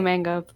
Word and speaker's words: manga: or manga, and I usually say manga, but that's probably not correct, technically speaking. – As someone who has done a lot of reached max level in their kanji training app, it is the correct pronunciation, but it doesn't really matter.